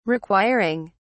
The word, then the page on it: requiring: British English